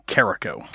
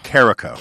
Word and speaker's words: Karako: But it's Tom Karako. – Karako. – Karako. – Okay. Because it looks like there's an extra A in there for some reason.